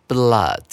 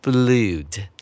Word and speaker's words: blood: 接下来我们看今天的最后一个单词 blood，注意发音不要念成 blood，这是一个特殊的发音哦。